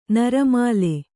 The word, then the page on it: ನರಮಾಲೆ: naramāle